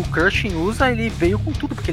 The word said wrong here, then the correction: Cruising USA: Como é que fala o nome desse jogo, cara? É Cruising USA.